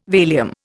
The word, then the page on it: Valium: Indian